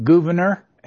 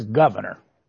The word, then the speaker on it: Gouverneur: I've had more than one historian tell me that you pronounce it Gouverneur and another one says Governor. How do you pronounce it? – Yeah, I pronounce it Gouverneur.